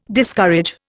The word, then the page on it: Discourage: Indian